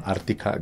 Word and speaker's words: Arctica: Sí, todas nuestras redes sociales son arroba Ártica guión bajo banda. – Hay que decir que Ártica se escribe ARCTICA.